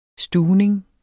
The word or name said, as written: stuvning